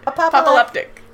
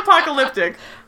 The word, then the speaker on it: apoplectic: Apoplectic. – It's not apocalyptic. Apoplectic. – Apoplectic. How do you say it? – Apoplectic. – Apoplectic? – Apoplectic. Apoplectic. Apoplectic. Jeez Louise.